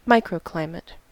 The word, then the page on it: microclimate: American English